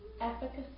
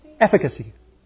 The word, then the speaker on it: efficacy: Efficacy. Is that how you pronounce the word? – Efficacy. – Efficacy. Thank you.